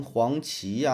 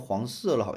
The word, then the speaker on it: 黄芪: 黄黄芪呀，我读成黄氏了，好像白是白珠嘛，嗯，我读成白术了。